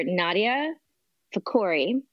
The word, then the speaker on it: Nadia Fakhoury: I am here today with Dr. Nadia Fakhoury, and I crushed it.